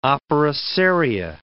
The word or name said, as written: opera seria